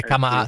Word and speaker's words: Camara: Tra l'altro, per chiudere, mi fa piacere che tu abbia detto Camara e non Camara, come dicono tutti, perché la pronuncia corretta è Camara, sarebbe Camara, come tutti i giocatori africani che noi ci ostiniamo ad accentare per l'influenza francese, ma se lo vai a chiedere a loro non c'è una neanche Ture.